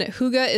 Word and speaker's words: Hygge: It's spelled H-Y-G-G-E. And it's a Danish word. – Okay. – But yeah, I guess you're supposed to say Hygge. – Okay. Oh, Hygge. I'm supposed to say Hygge? – Well, that's how you say it.